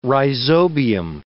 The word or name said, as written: rhizobium